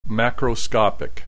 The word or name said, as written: macroscopic